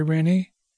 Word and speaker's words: Rinny: "Oh, I'm sorry, Rinny. It rhymes with Minnie, as in mouse."